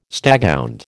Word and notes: staghound: American English

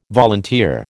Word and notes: volunteer: American English

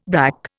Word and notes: brack: Indian

